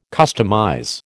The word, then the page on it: customize: American English